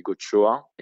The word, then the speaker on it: Goxoa: Et en plus, euh, normalement, si on prononce euh, bien, donc ça se dit gochoa.